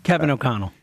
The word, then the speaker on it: O'Connell: This O'Connell guy, is that how you say it? O'Connell? – Kevin O'Connell.